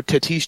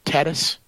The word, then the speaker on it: Tatis: Or is in your boy Fernando Tatis Jr., or some say Tatis, which I don't think is.